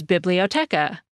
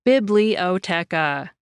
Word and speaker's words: biblioteca: The same word in Spanish is biblioteca. Each syllable in that word gets about the same emphasis, biblioteca.